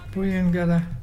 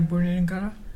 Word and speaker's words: Buyungarra: Buyungada is turtle, and it's spelled B-U-Y-U-N-G-A-R-R-A. Can you say Buyungada?